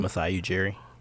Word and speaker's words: Masai Ujiri: How do you say his name? – Masai Ujiri.